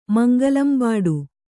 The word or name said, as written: ಮಂಗಲಂಬಾಡು